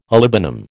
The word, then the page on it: olibanum: American English